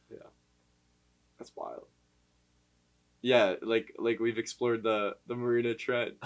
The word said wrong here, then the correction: Mariana: Mariana. – The Marina Trench. – Yeah. Update. – How do you say it? – We had a mishap last episode. It's called Mariana Trench, so.